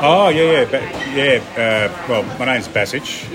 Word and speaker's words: Basich: And the little, where my actual grassroots from Dad comes from is called Basichi Selo, which is Basich Village.